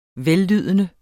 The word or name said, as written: vellydende